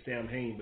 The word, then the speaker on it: Samhain: And it's really one of the, I guess you could say, holidays that they celebrate, because Samhain is one, it's not, it's spelled S-A-M-H-A-I-N, some people say Samhain, but it's actually Samhain.